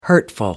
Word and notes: hurtful: American English

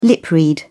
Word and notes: lip-read: British English

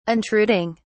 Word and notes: intruding: British English

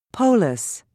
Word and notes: polis: British English

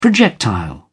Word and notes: projectile: British English

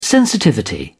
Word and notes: sensitivity: British English

